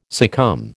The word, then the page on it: succumb: American English